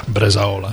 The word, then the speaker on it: bresaola: They do butter, a little bit of garlic, and a lot of cream and cow's milk cheeses and beef, bresaola. If I was saying that right. – Yeah, bresaola.